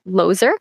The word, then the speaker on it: Lozer: If you can remember my first name, you'll remember the last because it's Lozer, but it's still spelled with a Z instead of an S. So it's like loser.